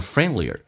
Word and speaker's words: friendlier: 而且关键那个 L 很难发，舌尖抬起来靠向你的上齿龈，在上牙齿后跟一点点，叫 O People And the people are nicer and friendlier. Friendlier 记住那个 D 是可以不用发音，在美式发音当中有一个规则，三个辅音并排，中间辅音可以直接去掉，所以 N D L 都是辅音， D 直接去掉就变成 Friendlier Friendlier Friendlier 指的是更加的友善。